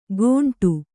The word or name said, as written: ಗೊಂಡು